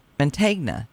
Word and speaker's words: Mantegna: including Joe Man- Mantegna.